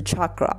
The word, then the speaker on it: chakra: 脉轮念法是 chakra。